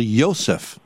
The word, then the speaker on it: Yosef: I know that in Hebrew, you, the correct pronunciation is actually Yosef. Yosef. – Mm. – And Loizo, L-O-I-Z-Z-O.